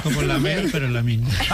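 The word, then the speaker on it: Lamine: ¿Lamín lo digo bien o cómo? – Sí, no, muy bien.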